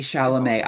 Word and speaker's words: Chalamet: Well, I'm excited to see Dune with my boy, Timothy Charmelet. Am I pronouncing his name right? – Timothy Chalamet.